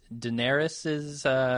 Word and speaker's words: Daenerys: The thing that I am most looking forward to is Daenerys's... is that how you say her name? Daenerys? – Sure.